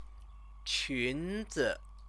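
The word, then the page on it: 裙子: qúnzi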